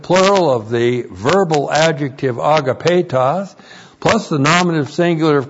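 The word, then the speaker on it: agapetos (ἀγαπητός): Agapetos. Looks like this in the Greek. That's A-G-A-P, long E-T-O-S, agapetos.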